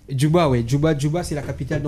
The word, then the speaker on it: Djouba: Indice, D-J-U. Donc, la première syllabe, c'est Jou. – Junabi. – Non. La deuxième syllabe n'a que 2 lettres. Et ce sont les 2 premières lettres de l'alphabet. Pardon ? – Djouba.